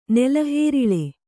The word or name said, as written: ನೆಲಹೇರಿಳೆ